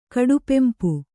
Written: ಕಡುಪೆಂಪು

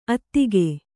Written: ಅತ್ತಿಗೆ

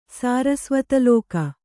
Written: ಸಾರಸ್ವತಲೋಕ

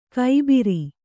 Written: ಗೊಬ್ಬರ

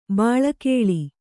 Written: ಬಲಿಗಲ್ಲು

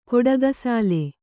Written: ಕೊಡಗಸಾಲೆ

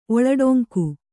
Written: ಒಳಡೊಂಕು

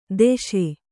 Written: ದೋಷಿ

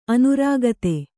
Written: ಅನುರಕ್ತೆ

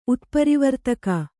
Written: ಉತ್ಪರಿವರ್ತಕ

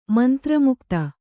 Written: ಮಂತ್ರಮುಗ್ಧೆ

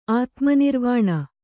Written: ಆತ್ಮನಿರ್ವಾಣ

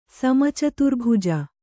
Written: ಸಮಚತುರ್ಭುಜ

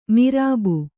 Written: ಮೊರಬು

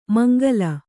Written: ಮಂಗಲ

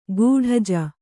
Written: ಗೂಢಜ

